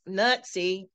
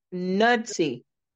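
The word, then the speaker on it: Knudsen: It's not pronounced Nutsy. It's Nudsy. KNUD. Nudsy. Not Nutsy.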